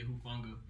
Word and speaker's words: Hufanga: Got Hufanga? – Yeah, Hufanga.